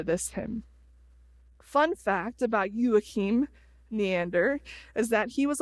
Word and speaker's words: Joachim: Joachim.